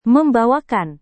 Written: membawakan

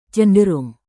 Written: cenderung

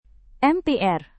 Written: MPR

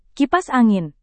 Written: kipas angin

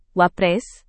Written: wapres

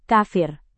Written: kafir

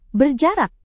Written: berjarak

